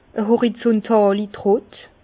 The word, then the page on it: e horizuntàli Trott: Bas-Rhin; Herrlisheim